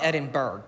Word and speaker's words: Edinburgh: Spelled Edinburgh, but I'm told it's pronounced Edinburgh.